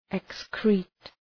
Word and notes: excrete: ık’skri:t

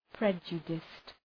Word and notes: prejudiced: ‘predʒədəst

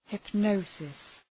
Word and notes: hypnosis: {hıp’nəʋsıs}

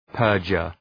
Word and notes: perjure: ‘pɜ:rdʒər

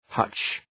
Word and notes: hutch: {hʌtʃ}

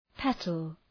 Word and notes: petal: ‘petəl

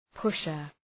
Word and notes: pusher: ‘pʋʃər

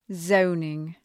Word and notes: zoning: 'zəʋnıŋ

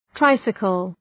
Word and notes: tricycle: ‘traısıkəl

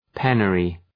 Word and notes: penury: ‘penjərı

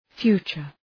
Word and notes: future: ‘fju:tʃər

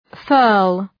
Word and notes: furl: fɜ:rl

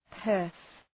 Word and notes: purse: pɜ:rs

